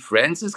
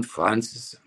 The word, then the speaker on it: Francis: Ja, der Francis hat mir mal gesagt, er wird nicht Francis gesprochen, wie man vielleicht denkt, sondern Francis.